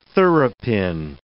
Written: thoroughpin

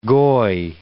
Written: goy

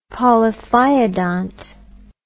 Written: polyphyodont

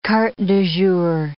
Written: cartes du jour

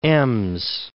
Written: Ems

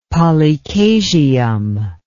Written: polychasium

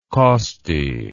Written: kosti

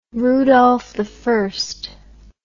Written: rudolfi

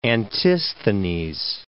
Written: Antisthenes

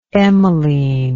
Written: emeline

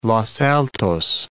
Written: Los Altos

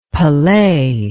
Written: Pelee

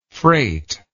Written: freightless

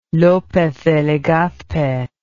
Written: lopez de legazpe

